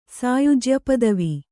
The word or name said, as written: ಸಾಯುಜ್ಯಪದವಿ